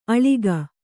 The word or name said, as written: ಅಳಕ